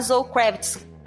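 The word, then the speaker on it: Zoë Kravitz: A Zoe Kravitz como Bonnie.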